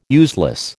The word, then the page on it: useless: American English